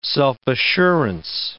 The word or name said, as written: self-assurance